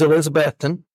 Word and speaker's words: Elizabethton: Am I saying that right? Elizabethton or Elizabethtown? – It's Elizabethton. – Elizabethton, Tennessee.